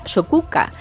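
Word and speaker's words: Sokółka: Dice que, claro, que por lo visto pues había producido, como comentamos, en Sokolka, que se pronuncia Sokuka.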